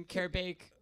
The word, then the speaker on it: Karabake: But they're really just looking for flagged countries and, you know, trying to disprove that the last name Karabake, because it rhymes with Arabic, is not...